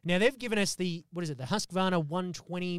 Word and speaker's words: Husqvarna: But this week, the good folks at Husqvarna. Is that how you pronounce it, Sebi? – Husqvarna Chainsaws. Husqvarna.